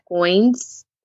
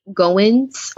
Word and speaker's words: Goins: The one book that will forever have a special place in my heart is The Art of Work by Jeff Goins, or like Goins, whatever way that you want to pronounce it, G-O-I-N-S.